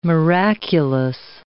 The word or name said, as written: miraculous